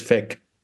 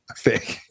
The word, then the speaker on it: PHEIC: Just get the pronunciation right and say it's FEC. – FEC, yes, a lot of FEC.